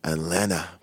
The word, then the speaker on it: Atlanta: J'adore ne pas prononcer le T de Atlanta comme les Américains. Atlanta. C'est comme Toronto, tu sais.